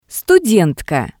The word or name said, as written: studentka